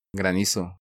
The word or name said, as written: Granizo